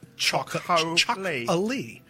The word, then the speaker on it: Txakoli: Well, chocolie is actually spelled T-X-A-K-O-L-I, and it is pronounced chocolie.